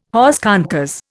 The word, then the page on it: hors concours: Indian